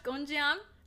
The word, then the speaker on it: Gonjiam: We're gonna go watch GONJIAM. – GONJIAM. No, GONJIAM. – Yep. You said it right the first time.